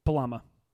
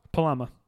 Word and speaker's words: palama: Sure. Your word is palama. – I just don't think you're saying it right. – I am saying it perfect. I'm looking at how it's pronounced, dude. – Palama? – Palama. Plural, palame.